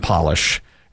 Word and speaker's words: Polish: It's Polish and polish. It's spelled P-O-L-I-S-H, Polish, and polish.